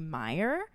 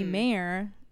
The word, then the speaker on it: Mayer: Most people, like literally half the time, they'll say Young Me Mayer, but it's Young Me Mayer, like mm-hmm. like you said. – John Mayer. – Yeah, like the singer, not the wiener.